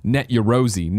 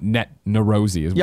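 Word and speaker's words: Net Yaroze: The PS1 dev kit was called Net Yerosi, not Net Nerosi.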